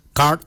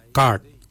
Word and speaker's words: Gaad: Guard is spelled G-A-A-D. Guard. The next word is hair. For hair, we say Jowin. Jowin. Jowin is spelled D-J-O-W-I-N-Y.